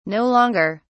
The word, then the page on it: no longer: British English